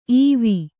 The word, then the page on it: ಇವ: iva